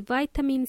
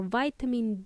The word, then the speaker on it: vitamin: So apart from flowers, we can also buy vitamins or supplements, such as vitamin C or vitamin D or vitamin E, and so on. The word vitamin has two pronunciations. You can either say vitamin or vitamin.